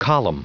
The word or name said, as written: column